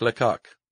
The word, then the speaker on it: Lecoq: Theater Jacques Lecoq. Is that how it's pronounced? – Yeah. – You sure it's not Lecoq? – No, it's definitely Lecoq.